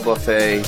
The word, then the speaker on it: buffet: $50 per person includes three cigars, Cuban style buffet. The T is silent in that word. Okay. Buffet.